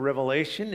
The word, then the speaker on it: Revelation: The last book of the New Testament is not entitled Revelations. A lot of times people pronounce it that way, but it's actually Revelation.